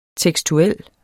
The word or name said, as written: tekstuel